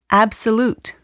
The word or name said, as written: absolute